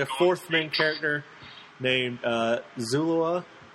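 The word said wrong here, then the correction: Killua: Is that how you say it? – Kilua. – Oh, Kilua. Yes, I read that completely wrong.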